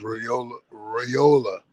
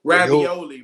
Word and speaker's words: Raiola: He said his name pronounced Raiola. – Ravioli, man.